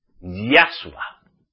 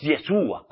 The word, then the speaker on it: Yahshua: Yahshua. No es Yeshua, como lo están diciendo por ahí, o Yehoshua. No.